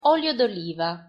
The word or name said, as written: olio d'oliva